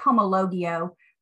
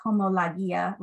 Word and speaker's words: homologia: It looks like homologio. But okay. The actual pronunciation is like homologia, lagia.